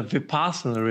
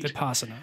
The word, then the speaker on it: Vipassana: You had mentioned that one of the biggest hurdles you've overcome in your life was your, and I can never pronounce this word, your Vipassana retreat. And I wanted to talk to you more about that. – Yeah, it's a Vipassana retreat. – Vipassana, okay.